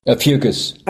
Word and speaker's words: Ophiuchus: And also, What about this little hidden constellation called, I'm probably going to pronounce this wrong, Ophiuchus? Ophiuchus? – Ophiuchus. – Ophiuchus, right.